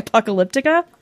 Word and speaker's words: Apocalyptica: It's Path by Apocalyptica.